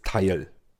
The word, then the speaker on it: Tile: Zunächst mal, es gibt also den Skill, der nennt sich natürlich auch Teil. Teil schreibt man Thiele, T-I-L-E.